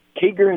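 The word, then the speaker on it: Kieger: Kiger, is that how you pronounce it? – Yes, it is, yep.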